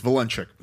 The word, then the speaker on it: Vilenchik: It's Vilenchek.